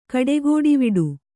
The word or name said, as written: ಕಡೆಗೋಡಿವಿಡು